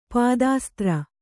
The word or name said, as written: ಪಾದಾಸ್ತ್ರ